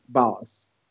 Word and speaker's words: Bawse: Because it's spelled B-A-W-S-E. Boss.